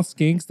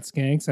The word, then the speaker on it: skinks: Wait, these are all skinks. They're not skanks. I've just been saying it wrong. – Skinks. – Skinks as in with like, an I.